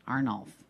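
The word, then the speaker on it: Arnulph: Arnulf, but it's A-R-N-U-L-P-H. Arnulf. – I've never heard of that saint. – I know. S-O-I-S-S-O-N-S.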